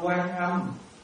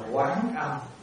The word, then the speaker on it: Quán Âm: Chữ quan á là mình nhìn bên ngoài, chữ quán là mình nhìn bên trong. Cho nên chúng ta không đọc quan âm, chúng ta phải đọc là quán âm.